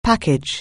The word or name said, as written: package